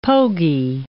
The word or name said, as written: pogy